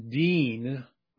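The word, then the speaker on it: din: The Hebrew word that's translated judge here is pronounced deen, or something very close to that.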